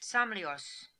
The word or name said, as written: Samlios